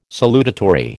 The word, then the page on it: salutatory: American English